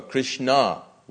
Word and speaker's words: Krishna: One of Radharani's names is also Krishna, with an accent on the A.